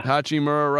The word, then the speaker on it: Hachimura: Hachimura, right.